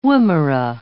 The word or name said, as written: woomera